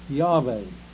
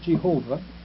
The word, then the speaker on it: Yahweh: Well, he was wrong in the first place, because the Jews don't have a hard J. They do have a J, but it's pronounced Yah. And if you're not sure about this, I'm just going to give you one instance. The Hebrew scriptures here and there say Hallelujah. Hallelujah. That means Yahweh. You see? Not Jeveh. Not Jehovah. It wouldn't have a hard sound.